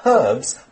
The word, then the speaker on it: herb: And in the U.S., we pronounce it herb. – Okay. – Or herbs. We don't say the H sound in the U.S. – But there is an H in the word, H-E-R-B. But both pronunciations are okay.